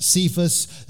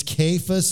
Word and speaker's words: Cephas: I know you're used to hearing this pronounced Cephas. That's kind of the English way to pronounce it. But this is actually an Aramaic word. Starts with a K when you transliterate it. It's Cephas is the way it was pronounced.